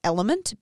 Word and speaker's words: LMNT: There's one brand, I'm sure there's more, but the one brand that I really like is called Element, but it's spelt L-M-N-T.